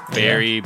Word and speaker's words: Bari: Is that how we're pronouncing that? – Yeah. Bari, the Bari.